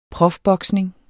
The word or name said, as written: profboksning